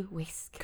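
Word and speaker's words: Wiske: Moved to the small town of Kirkby Whisk, which I think it's pronounced Whisk. Whisk? – Yeah. Whisk? – It's W-I-S-K-E. – Whisk. – Kirkby Whisk.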